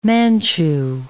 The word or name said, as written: Manchu